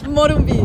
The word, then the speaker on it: Morumbi: Was he a real top player in Morumbi, no? The stadium, have I pronounced that correct? – Morumbi.